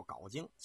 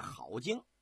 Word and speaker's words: 镐京: 咱们在这儿给您说一下，老百姓啊管这个地方叫镐京，其实应该念好京，它是个多音字。